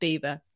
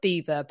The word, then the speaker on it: Belvoir: Is that the castle that's, the mental spelling, but it's pronounced Beaver. Yeah, the castle I'm going to, I'm touching wood, is pronounced Beaver, but it's spelt Belvoir, Belvoir.